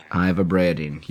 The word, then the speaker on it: ivabradine: Ivabradine.